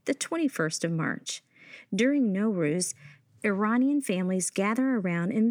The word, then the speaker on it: Nowruz: Like, the Persian word N-O-W pronounced no means new. And the word R-U-Z, ruz, means day. So Nowruz literally means starting a new day.